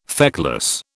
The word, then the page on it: feckless: British English